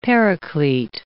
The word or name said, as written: Paraclete